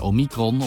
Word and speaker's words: Omicron: Dat is nog niet goed te zeggen, maar als je het vergelijkt met de Delta-variant, Daar ligt de klemtoon op lettergreep nummer 1. En bij Omicron op lettergreep nummer 2.